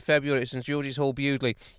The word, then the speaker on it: Bewdley: I'm thinking your 1st of February gig may well be in the Midlands again, in Bewdley, which I think is the pronunciation on that one.